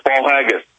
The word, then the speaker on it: Paul Haggis: It has been hailed by two Academy Award winner Paul Higgins as powerful and often chilling eye-opener. Hope I'm pronouncing that Paul Haggis. I think I'm pronouncing that right? – It is. It's Paul Haggis.